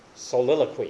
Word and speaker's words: soliloquy: Psalm 42 and Psalm 43 had the same type of soliloquy being used. Soliloquy, excuse me, get it pronounced correctly there for those of you who teach English.